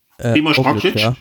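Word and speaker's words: Primož Roglič: Primož Roglic.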